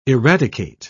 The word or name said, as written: eradicate